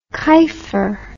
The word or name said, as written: kifer